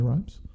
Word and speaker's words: Rhimes: What rhymes with Shonda Rhimes? Scott Grimes, but he's not in this. – Busta Rhymes? – Busta Rhymes. There you go. Busta Rhymes, Shonda Rhimes, Scott Grimes.